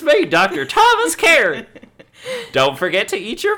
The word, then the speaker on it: Caritte: Spelled C-A-R-I-T-T-E, but it's pronounced Carrot.